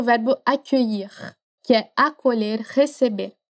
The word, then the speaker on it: accueillir: Mas pensa que a palavra accueillir, ela tem três partes: a, que, ir. Accueillir.